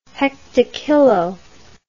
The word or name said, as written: hectokilo